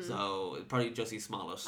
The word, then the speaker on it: Smollett: Speaking of bad things, is his name Jussie Smollett? – I think it is, and I would, listen, I don't know if this is right, but I've been saying Smollett. Two syllables, stress on the first syllable.